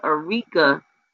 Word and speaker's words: Areca: Okay, and that's the Arica. It's spelled A-R-E-C-A, the Arica palm.